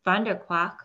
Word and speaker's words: Vanderkwaak: So, Lisa, tell us, you know, Vanderquack, did I say it right? – You got it.